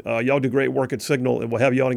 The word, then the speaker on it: Cygnal: John Rogers, Signal Analytics. That's spelled C-Y-G-N-A-L, Signal Analytics.